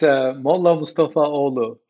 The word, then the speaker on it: Mollamustafaoglu: Sure, it's Mollamustafaoglu.